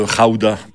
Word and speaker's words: Gouda: Le pays d'où vient le fromage, le Gouda ou le Rauda, parce qu'ils prononcent les G comme des R dans ce pays, c'est les Pays-Bas.